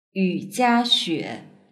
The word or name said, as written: yujiaxue